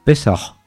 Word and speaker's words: Pesaj: Procede del hebreo, de una palabra hebrea muy antigua, que probablemente en hebreo antiguo se pronunciaría Pesaj.